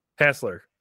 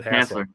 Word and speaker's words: Hasler: And I have Drew Hassel back on. And did I say your last name wrong? Hassler. I said Hassler. You're not a Hassel. You're a Hassler.